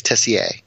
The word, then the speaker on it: Tessier: My dad, so my family's all from New England, right? And my dad says Tessier, but I don't pronounce it Tessier. So you're actually correct in your pronunciation. It just depends where you're at. I like to go with the more common French way of saying it, which is Tessier.